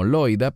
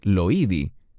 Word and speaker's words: Loida: Nosotros pronunciaríamos este nombre como Loida, pero en griego es Loidi.